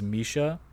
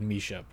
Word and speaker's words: Misia: I think they pronounced her name on TV as Misha, but the artist is spelled M-I-S-I-A.